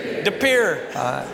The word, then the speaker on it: De Pere: Do you know this is a French word? How do we say this city? De Pere. So next time you go back to France, you got to say De Pere.